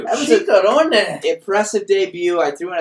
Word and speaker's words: Ciccarone: Ciccarone. Confirmed to be the pronunciation.